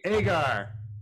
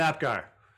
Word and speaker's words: agar: I'm like, Agar. – Long A. – Long A. But not Apgar. Don't do that. – Yes, there's no invisible P. It's just Agar.